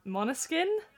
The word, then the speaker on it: Måneskin: What did an interviewer ask past Eurovision winners, excuse my pronunciation, Måneskin? – Yeah, that's right, that's perfect.